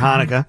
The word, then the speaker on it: Hanukkah: And you got the big H coming up, unless you're a CH, depending on how you want to pronounce it, for Hanukkah.